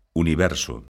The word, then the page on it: universo: lingua galega